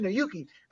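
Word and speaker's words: Aiyuk: Brandon Ayuki.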